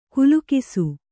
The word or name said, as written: ಗಿಲಿಗಿಸು